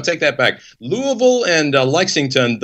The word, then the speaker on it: Louisville: Yeah, which everybody pronounces Louisville, Louisville. It's just Louisville. It's like you got punched in the mouth and tried to say Louisville.